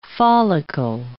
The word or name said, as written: follicle